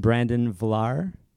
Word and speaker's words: Brandon Vlaar: And Brandon Vlaar? – Yeah, absolutely.